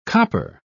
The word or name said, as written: copper